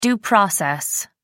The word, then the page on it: due process: American English